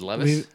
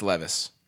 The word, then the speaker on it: Levis: Is Levis? – It's Levis. – Levis. – Huh. – Yeah, and I would have guessed that. – I thought it was Levi's because that's what I've heard.